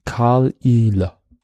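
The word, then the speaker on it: Caol Ila: Und es ist auch nicht ein schnell gesprochenes I, sondern ein langsam gesprochenes. Also ein Ile. Karl Ile.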